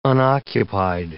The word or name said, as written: unoccupied